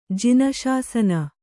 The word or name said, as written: ಜೈನಶಾಸನ